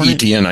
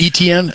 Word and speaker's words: Etienne: How do you pronounce that guy's name? The running back? You know, Travis Etienne, I think. – Etienne? Okay. Etienne.